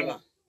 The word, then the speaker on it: Decatur: So, Lacey Spears was born and raised in Decatur, I hope I said it right, Alabama. – Decatur. – Huh? – Decatur. – Decatur? Okay. Decatur, Alabama, where she lived until she gave birth to Garnett.